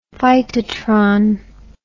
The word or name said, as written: phytotron